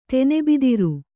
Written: ತೆನೆಬಿದಿರು

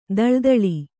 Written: ಥಳಥಳ

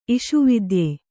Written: ಇಷುವಿದ್ಯೆ

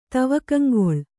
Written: ತವಕಂಗೊಳ್

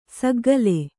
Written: ಸೆಖೆಕಾಲ

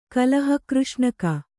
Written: ಕಲಹಕೃಷ್ಣಕ

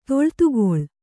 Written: ತೊಳ್ತುಗೊಳ್

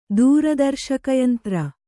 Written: ದೂರದರ್ಶಕಯಂತ್ರ

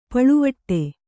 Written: ಪಳುವಟ್ಟೆ

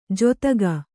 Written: ಜೊತ್ತಗ

